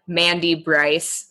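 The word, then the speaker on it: Mandie Brice: So everything is consistently Mandy Bryce.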